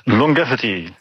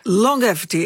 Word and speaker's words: longevity: Longevity. – Longevity.